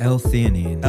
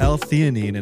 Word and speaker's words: L-theanine: L-theanine. – L-theanine